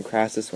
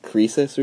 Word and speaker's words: Croesus: So I think that Greek guy who ruled over Anatolia, I think I pronounced his name Crassus when it's like Croesus or something like that.